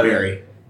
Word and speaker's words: Bury: That does not say Bury. – It is how you pronounce that. Bury. They are just outside of Manchester.